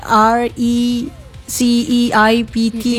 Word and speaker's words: receipt: Receipt or receipt? – Receipt ya. – Receipt. Oke. – Receipt. P-nya enggak dibaca tuh mampu dua.